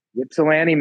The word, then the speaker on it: Ypsilanti: I'm going to butcher this, but is it Ypsilanti? – Ypsilanti, Michigan.